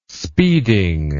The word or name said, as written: speeding